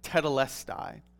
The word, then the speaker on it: tetelestai: The word that's used here is a Greek word that's pronounced something like tetelestai.